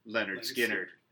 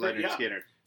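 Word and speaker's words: Lynyrd Skynyrd: All right, so the album I'm doing is Skinner's first album, pronounced Leonard Skinner. Which is actually helpful, because as everyone will see looking at the band name, it doesn't look like it's pronounced Leonard Skinner.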